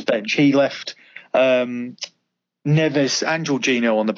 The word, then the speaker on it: Jorginho: How do you say the Chelsea midfielder, Jorginho? – Jorginho, only 5 million, yeah.